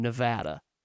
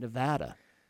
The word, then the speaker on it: Nevada: That's what I said for years until I met someone from Nevada. That's how they say it, Nevada.